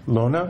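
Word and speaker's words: Lonah: Well, this runner is a great example of you know, and I, I, don't know how to pronounce her name. What's her first Lona. name? Lona. Mm-hmm.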